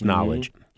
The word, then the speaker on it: Knxwledge: Now, Knowledge, and it's spelled K-N-X-W-L-E-D-G-E, but it's pronounced Knowledge.